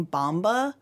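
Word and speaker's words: Bombur: I hadn't heard it spoken, Andy Serkis, while reading it, and I think in the movie, it almost sounds like they sang Bomba. – Oh, which, yeah. – But it's spelled Bomber, so. – Yeah. And it also, like, their accent probably doesn't give it that hard R at the end. – Yeah. – But it is Bomber.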